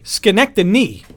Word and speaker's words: Schenectady: Schenectady, N-Y, like New York, not Schenectady, D-Y. – All right. – Like do it yourself.